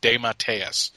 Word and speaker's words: DeMatteis: I got to remember that that's how it's pronounced, DeMatteis.